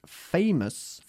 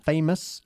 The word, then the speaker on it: famous: Muy importante también es la pronunciación de la palabra famous. Famous y no famous o famous, okay? Famous, say it.